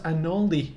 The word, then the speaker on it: anole: I mean, I think we actually might wind up adding a whole segment for Anoles, because there's constantly Anole stuff coming out. – Yes. – As long as we call it Does Anal. – Only that it's pronounced. Only that it's pronounced Anoli, not Anole.